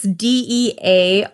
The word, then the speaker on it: Dearybury: It's Dearybury. I'm spelling it for you because to me, that does not say Dearybury, but that is how he pronounces it. So that's how I'm going to say it.